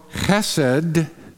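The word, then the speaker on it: chesed (חסד): But you have to have a little saliva in your mouth when you say that H, chesed.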